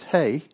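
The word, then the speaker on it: hey (ה): And then the final letter that is used is hey, H-E, and the E is pronounced as an A, and it's a window.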